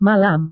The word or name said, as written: malam